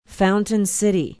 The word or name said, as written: fountain city